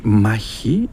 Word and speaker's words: Machi: Donc Maquis a prononcé Machi, effectivement, la translittération en kh, c'est le ch grec, la lettre ch. Machi, c'est la bataille en grec.